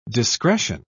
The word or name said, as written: discretion